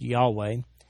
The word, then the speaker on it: Yahweh: When you see in all caps the word L O R D, capital L, capital O, capital R, capital D, the Hebrew equivalent there is Jehovah, sometimes pronounced Yahweh.